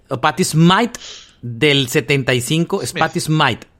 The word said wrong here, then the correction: Smith: Yo creo que es Smith, porque su apellido es Smith. – Sí, pero hay una diferencia entre cómo se pronuncia.